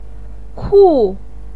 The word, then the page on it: ku: 4